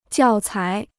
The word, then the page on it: 教材: jiào cái